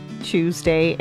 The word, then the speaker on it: Tuesday: I almost say as if the word is like chew, C-H-E-W, Tuesday.